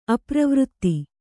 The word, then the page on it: ಅಪ್ರವೃತ್ತಿ: apravřtti